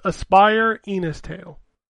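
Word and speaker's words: Aspire: Ina's Tale: It's Aspire Enos Tale. – I'm assuming it's spelled, like, normal Aspire. – A-S-P-I-R-E.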